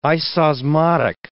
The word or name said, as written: isosmotic